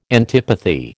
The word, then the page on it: antipathy: American English